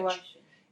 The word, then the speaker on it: washing: Escuchas, identificas bien el shh, SH. Washing. Watching.